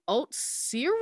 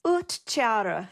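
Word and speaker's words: uhtceare: Is it oat cereal? – It's oot chara.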